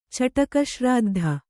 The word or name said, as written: ಚಟಕಶ್ರಾದ್ಧ